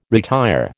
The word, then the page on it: retire: American English